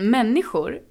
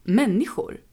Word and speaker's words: människor: And människor is a hard, människor. That's a hard thing to pronounce, that sh sound, because you cannot make it to sh.